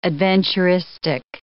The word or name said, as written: adventuristic